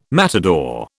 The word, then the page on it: matador: British English